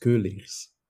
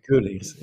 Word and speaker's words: Keuleers: It's pronounced Keuleers. – Keuleers. Okay. – Keuleers. Yeah. Typical right. Dutch vowel, which is very unusual in other languages.